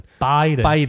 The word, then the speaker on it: Biden: Biden. I do this with my B's. – Biden.